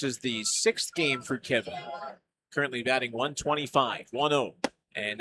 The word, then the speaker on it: Kevyn: So Burns is retired, and now it's the left fielder Kevin Castillo digging in on the left side. Now, Kevin, pronounced how you would, but spelled K-E-V-Y-N.